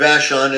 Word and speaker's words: Vashon: I'd like to visit, is it Vashon Island? Is that how you say it? – Yeah, we're on Maury Island, but Vashon is the designated postal address.